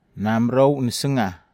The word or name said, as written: namerou nesega